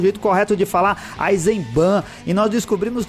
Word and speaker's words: Eisenbahn: Então a pronúncia correta seria Eisenbahn, com N, igual Autobahn, com N. E eu também falo meio nasalado, então às vezes pode parecer que você engole o N, mas sim, ele deve estar presente.